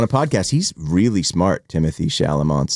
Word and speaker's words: Timothée Chalamet: Instead, they made one Timothy Chalamet. – Timothée Chalamet. – Oh, Timothée.